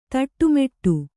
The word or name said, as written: ತಿಟ್ಟಮಿಡು